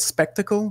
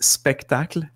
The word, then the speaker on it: Spectacle: Like it's, let's take, for example, one of my games, Spectacle, which is, people call it Spectacle, but it's in the title. It's, you need to pronounce it a la French. So it's Spectacle.